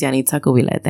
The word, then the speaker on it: Yanitza Cubilette: because my name is Yanitza Cubilette.